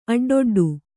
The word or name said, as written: ಅಡ್ಡೊಡ್ಡು